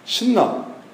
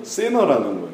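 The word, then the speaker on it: thinner: 신나. 이게 일본식 발음인데 영어로는 세너라는 거예요.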